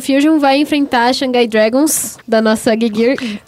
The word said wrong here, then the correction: Geguri: Vai, é, Shanghai! Geguri. Estou ruim de pronúncia hoje.